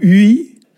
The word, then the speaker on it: vi: Ça s'écrit vi ou ui. Et ici, dans le Kreis Breizh, on prononce u.